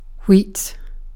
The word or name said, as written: wheat